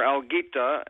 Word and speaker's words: Alguita: The Spanish for kelp is alga, and we're a little kelp plant. We're Alguita, and our – Research Foundation is Algolita.